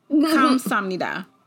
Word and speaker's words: Kamsahamnida: Kamsahamnida. Does that sound right? Mm-hmm. That sounds right.